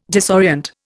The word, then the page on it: disorient: Indian